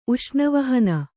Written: ಉಷ್ಣವಹನ

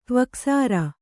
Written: ತ್ವಕ್ಸಾರ

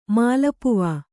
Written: ಮಾಲಪುವಾ

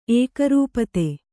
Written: ಏಕರೂಪತೆ

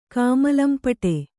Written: ಕಾಮಲಂಪಟೆ

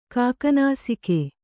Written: ಕಾಕನಾಸಿಕೆ

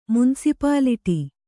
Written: ಮುನಿಸಿಪಾಲಿಟಿ

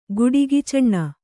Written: ಗುಡಿಗಿಚಣ್ಣ

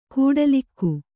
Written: ಕೋಟಲಿಗ

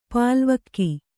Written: ಪಾಲ್ವಕ್ಕಿ

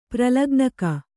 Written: ಪ್ರಲಗ್ನಕ

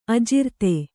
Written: ಅಜಿರ್ತೆ

